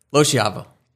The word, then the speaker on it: Loschiavo: Loschiavo. – Can you like roll your R with that? – Or? No, you're good. No.